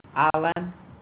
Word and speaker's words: Alan: Alan. – Can you spell that for me? – A-L-A-N. – A-L-A-N, and what's your last name? – Garcia.